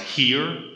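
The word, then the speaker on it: hir: Then you also at the bottom, you have Z, H-I-R, which is pronounced here.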